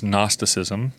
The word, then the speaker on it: Gnosticism: is Gnosticism, which is spelled with a G, like Gnus or Gnostics.